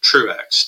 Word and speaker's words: Truax: My last name, Truax, T-R-U-A-X.